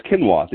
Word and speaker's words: quinoa: Quinoa? How do you say that? Quinoa. I can never. Okay. Favorite oats? Quinoa. There we go.